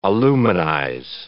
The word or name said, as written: aluminize